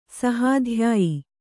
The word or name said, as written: ಸಹಾಧ್ಯಾಯಿ